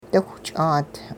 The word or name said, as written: diwich'aat